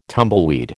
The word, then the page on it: tumbleweed: American English